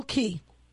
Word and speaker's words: Caye: Spell Key. It's not K-E-Y. – Key? Oh, C-A-Y-E. – Right, and it's pronounced Key.